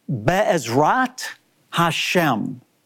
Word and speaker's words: Be'ezrat Hashem: It's pronounced like this, Be'ezrat Hashem. Be'ezrat Hashem.